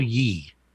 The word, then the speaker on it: Ye: It says it's spelled Ye. – Y-E is Ye. – Yes, Ye. Y-E. Like the last two digits of his name, which is why I think it's like Yay.